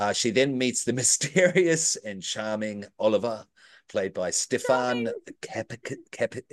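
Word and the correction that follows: Kapičić: Anyone? – Kapichik. – Thank you. – Kapichik.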